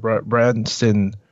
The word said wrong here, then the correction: Bryson: Yeah, is it Bryson? – Bryson. I butchered it.